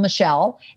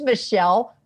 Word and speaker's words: Michele: Well, I'm Michele Borba, but I'm a 1L Michele, and Borba rhymes with Zorba. So it's Michele Borba.